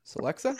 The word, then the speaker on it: Celexa: Celiex. It's C-E-L-E-X-A. I'm probably mispronouncing it. It's Celexa? – Fairly. Yeah, Celexa.